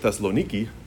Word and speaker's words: Thessaloniki: So as we've already seen it in previous weeks, Thessalonica, or as the Greeks like to pronounce it, Thessaloniki, is this city in northern Greece.